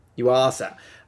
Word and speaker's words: Yuasa: Directed by Shigeo Tanaka, which he's directing it because Noriaki Yuasa, how do you say that name? – Yuasa.